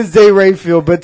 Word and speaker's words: Rafael: D-A-N-R-A-F-A-E-L. – Don Ralph. – R-A-F-A-E-L. Raphael. – Don Ralph. – No. It's Raphael. Dan Raphael. – How do you spell Raphael? – R-A-F-I-E-L? – That's Raphael. – R-A-P-H-A-E-L.